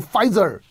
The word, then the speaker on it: Pfizer: 复的 F，复就是 Pfizer，辉瑞是 Pfizer， P 不发音 F Pfizer。 B 呢？B 就是 Bio B I O，生物嘛，生技 B I O。泰呢？泰就是 Tech Biotech，生物科技就是 B 泰。 Pfizer Biotech 啊，不就是 BNT 吗？